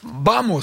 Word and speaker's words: Vamos: Vamos, ça se prononce comme ça. – Tout à fait. – Le V se prononce B en espagnol, je ne sais pas, je ne parle pas espagnol.